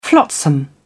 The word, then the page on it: flotsam: British English